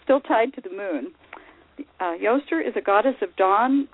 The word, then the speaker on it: Eostre: Goddess for March 22nd is Yostre. It's spelled E-O-S-T-R-E, and by the way, it's the origin of the word Easter.